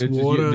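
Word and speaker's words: Woujder: It's Warda. – The J is silent. – Everybody's W-O-U-J-D-E-R.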